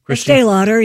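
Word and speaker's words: Estée Lauder: Is it pronounced Estee Lauder? – Estee Lauder, yes.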